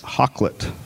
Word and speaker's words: Hakluyt: Richard Hakluyt? – Hakluyt. – Okay. – Yeah. I actually looked up the pronunciation of that one. – Proud of you, baby. – Because it's the way it's spelled is fucked up.